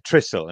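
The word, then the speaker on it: Trysil: When Bex went to Trissel, and is that pronunciation correct, Tom? – The pronunciation is absolutely perfect.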